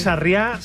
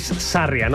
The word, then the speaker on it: Sarria: Es que no sé si es Sarriá, Sarria, bueno, porque como no lleva tilde, me tienen confundido, fíjate. – Por favor, que alguien nos mande un audio y nos lo aclare. – Bueno, alguien con buen conocimiento me dicen que es Sarria, no Sarriá.